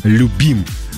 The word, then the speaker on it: Любим: Юля, город Любим. Правильно, вот Юля написала ударение на «и».